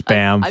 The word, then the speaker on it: Bamford: It's Bamford. – I've caught you a couple times. – B-A-M. It's not Ban. It's Bam.